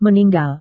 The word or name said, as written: meninggal